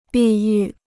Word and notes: 碧玉: bì yù

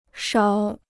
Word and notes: 勺: sháo

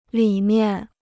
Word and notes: 里面: lǐ miàn

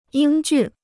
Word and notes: 英俊: yīng jùn

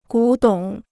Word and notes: 古董: gǔ dǒng

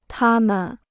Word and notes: 她们: tā men

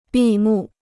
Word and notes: 闭幕: bì mù

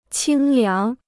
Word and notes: 清凉: qīng liáng

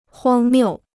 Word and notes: 荒谬: huāng miù

